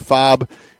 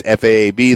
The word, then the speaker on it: FAAB: Some people pronounce it FOB. It's FAAB, that's free agent acquisition budget.